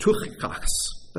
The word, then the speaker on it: Tychicus: In Greek, it's pronounced Tychicus. That's why we say Tychicus instead.